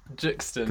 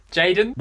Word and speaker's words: JXDN: Juxton. – God. – Jaden.